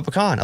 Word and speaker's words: pecan: It is pecan. – Not pecan? – Not pecan, not pecan. It's pecan.